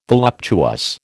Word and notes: voluptuous: American English

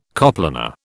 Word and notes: coplanar: British English

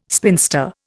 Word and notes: spinster: Indian

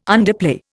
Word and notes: underplay: Indian

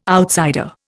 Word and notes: outsider: Indian

